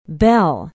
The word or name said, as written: bell